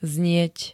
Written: znieť

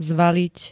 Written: zvaliť

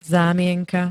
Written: zámienka

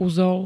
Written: uzol

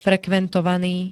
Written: frekventovaný

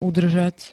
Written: udržať

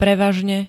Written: prevažne

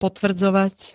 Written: potvrdzovať

